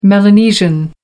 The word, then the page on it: melanesian: American English